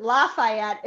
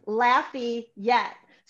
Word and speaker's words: Lafayette: But also, interestingly enough, if you live in Lafayette, you don't pronounce it Lafayette. It's Lafayette.